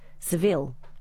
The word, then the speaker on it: Seville: Apparently it's pronounced Seville after the city in Spain.